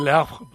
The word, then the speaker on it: Le Havre: Se dice Le Havre, porque la E final en francés no se pronuncia.